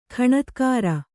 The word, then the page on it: ಖಣತ್ಕಾರ: Kannada